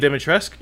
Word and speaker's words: Dimitrescu: Because it sounded like she just sucker punched the Lady Dimitrescu. Yeah, I think that's the actual pronunciation. – Oh, the U is silent? – Yeah.